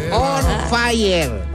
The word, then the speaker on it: Onfayer: On Fire, pero no se escribe On Fire como en inglés, sino que se escribe O-N-F-A-Y-E-R. – Repítalo, mija, por favor. – O-N-F-A-Y-E-R. On Fire. – Fire. – Así, tal como suena, Fire.